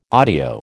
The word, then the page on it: audio: American English